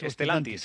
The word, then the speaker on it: Stellantis: Por cierto, la planta automovilística de Stellantis de Villaverde, ¿se pronuncia así? ¿Stellantis? – Stellantis, eso es.